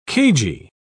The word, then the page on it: cagy: British English